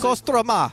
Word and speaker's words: Kostroma: Can you pronounce Kostroma. – It? Kostroma. Kostroma. You are doing it really well.